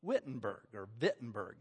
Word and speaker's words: Wittenberg: There's a new university starting over in the town of Wittenberg is how it should be pronounced.